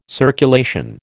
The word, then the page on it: circulation: American English